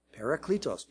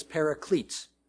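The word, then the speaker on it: parakletos (παράκλητος): And this word translated as comforter or helper, many of you already know, is parakletos. Parakletos, P-A-R-A-K-L-E-T-O-S. Sometimes pronounced as paraclete.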